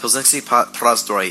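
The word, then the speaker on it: Plzeňský Prazdroj: is Pilsenský prazdroj. – Mm-hmm. – Pilsenský prazdroj is what you'll hear here in Czech Republic when you say you want a Pilsner Urquell.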